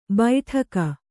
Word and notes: ಬೈಠಕ: Kannada

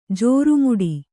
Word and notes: ಜಾರುಮೆಟ್ಟು: jāru meṭṭu

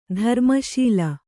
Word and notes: ಧರ್ಮಶಾಲೆ: dharma śale; Kannada

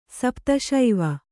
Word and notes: ಸಪ್ತಶೈವ: kannada

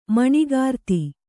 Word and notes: ಮಣಿಗಾರ್ತಿ: kannada

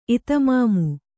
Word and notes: ಇತಮಾಮು: kannada

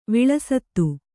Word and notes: ವಿಳಸಿತ: kannada

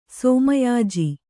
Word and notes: ಸೋಮಯಾಜಿ: Kannada

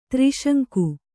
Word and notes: ತ್ರಿಶಂಕು: tri śaŋku

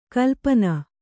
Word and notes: ಕಲ್ಪನೆ: kalpane

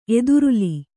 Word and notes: ಎದುರುಲಿ: eduruli

